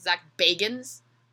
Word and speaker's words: Bagans: Also, apparently it's not Zach Baggins, it's Zach Bagans.